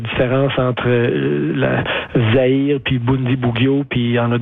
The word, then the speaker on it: Bundibugyo: Je pense qu'il s'appelle Bundibugyo. Je ne sais pas si je le prononce comme il faut. – Bundibugyo. – Voilà. – Ouais, Bundibugyo. Ben, la taxonomie là, des virus est un peu compliquée.